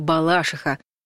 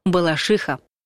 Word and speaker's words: Балашиха: Большинство людей говорят «Балашиха», просто потому, что так привычнее звучит. хотя сами жители Балашихи протестуют против такого и отстаивают ударение на предпоследнем слоге «Балашиха».